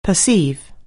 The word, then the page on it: perceive: anglais